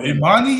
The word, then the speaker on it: Imani: I believe he transferred from – Imani, you mean? – Yeah. – Imani, yeah. – Okay, mispronounced. – You said Anthony, man. I don't know Anthony Bates, man. – I said Emony Bates, but – Emony.